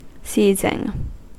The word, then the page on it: seizing: American English